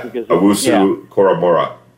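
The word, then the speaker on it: Owusu-Koramoah: Owusu Koramora.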